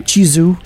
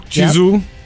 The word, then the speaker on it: Chizu: So I will tell you, Heather and I were talking about your coming on, and we're so happy about Chizu. Is that how you pronounce it? Chizu? – Chizu. – Chizu. – Yep.